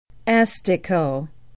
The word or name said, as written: astico